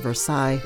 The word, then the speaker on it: Versailles: It's time to cook dinner, and my son is hard at work writing his history report on the Treaty of Versailles. – Is that how you say it? – Yep, it's French.